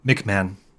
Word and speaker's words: McMahan: Oh, it's McMahan.